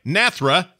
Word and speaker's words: Nathria: Nathria.